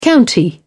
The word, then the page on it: county: British English